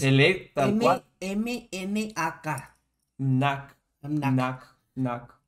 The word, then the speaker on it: Mnak: Lo voy a pronunciar como yo lo imagino. Nac. M-N-A-K T, MNAC. – Entonces. Ah, pero en España se pronuncia las letras tal cual. NAC.